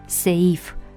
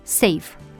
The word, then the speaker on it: safe: Un asemenea caz îl reprezintă anglicismul adaptat grafic safe, care nu trebuie pronunțat în două silabe și cu accent pe i, seif, ci ca în engleză într-o singură silabă, safe.